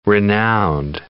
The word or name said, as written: renowned